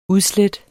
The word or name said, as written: udslæt